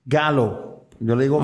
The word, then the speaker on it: Gallo: No, e, en inglés y la pronunciación en inglés es galo, pero hay mucha gente que le dice yo y gallo, pues, pues cómo se escribe.